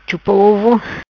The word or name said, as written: Chupa Ovo